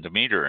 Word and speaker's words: Demeter: So I guess I've always said Demeter, and I guess it's Demeter. It's probably correct way to say it. – You were right. If you were in Germany, you were right.